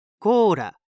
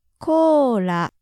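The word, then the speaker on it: Kōra (コーラ): And a quick note on the pronunciation. Listen carefully, and hopefully you can hear that it's not really a pure L sound. – Kōra. La. La. – It's close to an L, but more similar to a light D sound.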